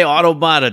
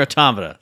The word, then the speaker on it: Automata: Now, don't say Automata. Don't do it. It's Nier Automata.